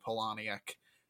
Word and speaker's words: Palahniuk: I know how to pronounce that because I had a therapist who lived in the same town as Chuck Palahniuk, and for some reason he came up and he's like, yeah, it's pronounced Palahniuk.